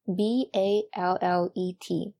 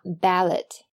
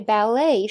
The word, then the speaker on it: ballet: 比如说芭蕾舞这个词，可能很多同学都会拼 b a l l e t，那要怎么发呢？千万不要一激动就读成了 ballet，其实它的读音应该是 ballet，ballet，重音在后边，并且最后的 t 是不发音的。那究其原因呢，是因为 ballet 实际上是法语的外来词，而在法语中呢，结尾的 t 通常是不发音的。